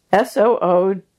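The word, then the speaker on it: Sooji Chim: We're going to spell that. It's Soojichin. – Exactly. – Or Seokeum. – Exactly. Yeah. – Okay. – Yeah. – So I'll just do that one more time. Soojichin or Seokeum or Korean hand therapy.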